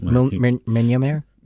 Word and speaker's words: Mjolnir: this moment where Cap picks up the Mjolnir, is that how you pronounce it? – Mjolnir? – Yes, that thing.